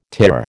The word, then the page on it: TIR: American English